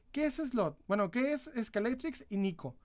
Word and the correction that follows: Ninco: Ninco. Perdóname por equivocarme con la pronunciación.